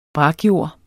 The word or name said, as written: brakjord